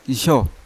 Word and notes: isyo: Yawa

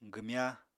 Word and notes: gwmya: apatani

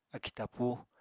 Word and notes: akì tapúu: apatani

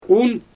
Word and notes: un: Xyzyl

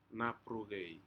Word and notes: naprucei: Aneityum

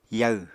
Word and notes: yaw: apatani

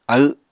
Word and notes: aw: apatani

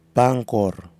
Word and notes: pangkor: Nafe

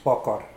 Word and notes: pwoakoar: Mokilese

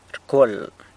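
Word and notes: rcwual: San Lucas Quiaviní Zapotec

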